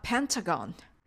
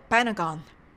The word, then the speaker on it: Pentagon: 5 はペンタ、五角形はペンタゴン。アメリカ人やカナダ人の人が発音するとパナゴンと聞こえることがあります。